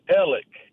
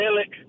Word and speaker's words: Alex: But when our lockers were next to each other, and he pronounced his name Elik, E-L-I-K, and it's because he's from that aristocratic Fritz Hollins area of the Low Country. You know, Elik, go down the lane a ways and get me some milk. So that's why his name is Alex, and they pronounce it Elik.